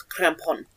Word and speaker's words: Krampus: It's like Krampus. – It's actually Bavarian, and it's Krampon. – Krampon. – Yeah. – Krampon. Sounds like you're saying crap. It's like you're saying crap on.